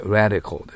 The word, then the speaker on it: radical: でも英語の発音は青の発音で radical です。これを気をつけてください。